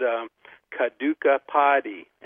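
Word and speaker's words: Kadukkai Podi: and it's called Kaduka Padi, and that's spelled K-A-D-U-K-K-A Padi, P-O-D-I.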